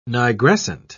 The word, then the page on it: nigrescent: British English